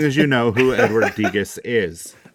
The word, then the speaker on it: Degas: I think you just need to ask yourself anytime you're worried, just say, what would Edward Degas do? – Degas. No, that is not correct. Degas. Degas. – Edward Degas. Yeah. I'm sorry. Mispronounced that.